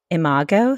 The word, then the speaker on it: imago: Jessica is also a certified, now am I, tell me if I'm saying this right, imago therapist? – Yes, yes, you are.